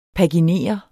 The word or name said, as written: paginere